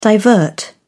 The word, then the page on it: divert: British English